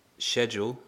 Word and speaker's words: schedule: And there's another word that is quite different between pronunciation in America and in British English, and that's schedule.